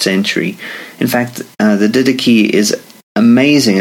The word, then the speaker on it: Didache: It's, it's spelled did ache, but pronounced Didache.